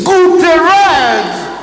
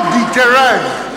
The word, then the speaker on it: Guterres: Guterres is the answer. Not Guterres.